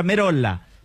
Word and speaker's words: Merolla: Deberíamos, pero si te vas al purismo, es un apellido italiano y en italiano se pronuncia Merolla.